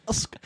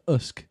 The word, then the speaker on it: Usyk: Alexander Usk. – Was that... Sorry, did you sneeze? – On point. – Alexander Usk. Alexander Usk. And how's it spelled, French? – U-S-Y-K.